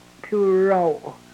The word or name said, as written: pyurow